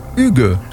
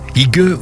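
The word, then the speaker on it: hygge: Alors, tu me corriges hein, si je me trompe, le hugue. – Voilà, hugue ou hugue, selon qu'on essaie de prononcer ce mot à la française. – C'est écrit avec un Y.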